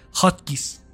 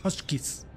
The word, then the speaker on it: Hotchkiss: No sé cómo se pronuncia, disculpen las que hablen inglés chingón. Hotkeys. Se escribe Hotchkiss. Hotkiss. Yo lo he escuchado como Hotchkiss.